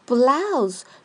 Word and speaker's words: blouse: 第四个单词 blouse，注意 o u 的发音，ow，ow，blouse，blouse。 Blouse 是指带花边领子的上衣，多数用来说女孩子的衬衫，男孩子是不能穿的哦。